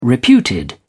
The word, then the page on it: reputed: British English